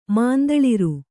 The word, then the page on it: ಮುಂತಳರ್: Kannada